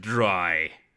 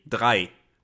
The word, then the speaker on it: drei: It's probably like they heard the German word for three, drei. And they thought, okay, dry. – Dry. – But why? – Eins, zwei, drei.